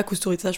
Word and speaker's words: Kusturica: Et en fait, euh, j'étais fan, enfin, on était fan dans ma famille des films de, de Emir Kusturica. Kusturica, je pourrais, on peut dire les deux prononciations.